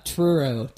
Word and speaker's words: Truro: I thank you for the pronunciation guide, but could you just pronounce it for us here so that I don't screw it up in the intro? – Yeah, it's, Truro, T-R-U-R-O, church, which I still, my wife works at the church as well.